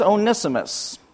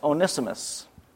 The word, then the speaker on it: Onesimus: If you were wondering how to pronounce it, it is Onesimus. You may try to say it one-isimus or whatever, but it's Onesimus.